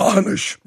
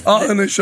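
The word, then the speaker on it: Aughinish: Now, because we are fair journalists here, it does have a response from the alumina companies, the how do you say this company? – Anish. – That's Anish Alumina.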